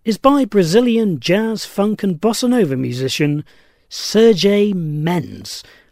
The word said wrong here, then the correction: Sergio Mendes: I may have pronounced that wrong. Sergio Mendes. S-E-R-G-I-O-M-E-N-D-E-S.